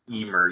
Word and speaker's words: Ymir: How do you pronounce that? – Is it Ymir. – Ymir, yeah.